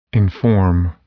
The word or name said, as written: inform